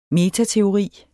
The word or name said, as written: metateori